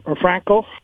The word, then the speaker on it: Franchi: Franco, F-R-A-N-C-H-I. It's pronounced different than what it's spelled.